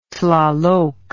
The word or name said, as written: Tlaloc